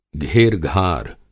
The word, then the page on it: घेरघार: Hindi